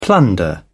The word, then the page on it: plunder: British English